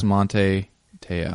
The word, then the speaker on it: Manti Te'o: Manti Te'o. Close? – Don't know. I'm impressed, and I like I've heard it pronounced about 8 different times. I think it's Monte Teo. I think she's right.